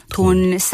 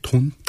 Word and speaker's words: 돈: And if it's pronounced in Chinese character, it's 돈. 돈 sounds the same as money in Korean. – That's how they say pig, 돈? – 돈.